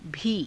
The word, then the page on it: bhii: hindi